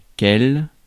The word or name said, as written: which